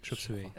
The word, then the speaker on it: Chop Suey: Euh, bah, je connaissais bien, bah, bien sûr celle que tu as citée tout à l'heure sur Toxicity, euh, Chop Suey, ça se prononce comme ça Chop Suey.